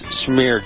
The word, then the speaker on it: schmear: Transportation Security Administration, the headline is Schmear Job. Schmear Job. I did pronounce that right. I know it sounds like I'm slurring my words. It's schmear.